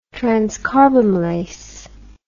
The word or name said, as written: transcarbamylase